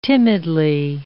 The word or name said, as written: timidly